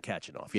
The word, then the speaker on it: Khachanov: Karen Khachanov, yeah.